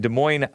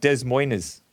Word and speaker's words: Des Moines: So the Dems duked it out last night in Des Moines, Iowa. I pronounced it right. It's not Des Moines.